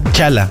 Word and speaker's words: T'Challa: ¿Cómo se pronuncia? ¿T'Challa o T'Challa? T'Challa. T'Challa, sí, ¿no? T'Challa.